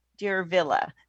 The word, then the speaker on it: Diervilla: I know it'll be in our show notes, but I do want to say it's DIERVILLA, Deer Villa. So it's kind of an interesting, it sounds like a Disney villain. – Right? It does though, doesn't it? Yes, yeah. That's exactly what I had thought. – Yeah. – I'm trying to pronounce it. Dervilla, like Cruella.